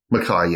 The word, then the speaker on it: Mackay: Mackay, yes. – Mackay. – Yeah. Okay. It'll be the Scottish pronunciation.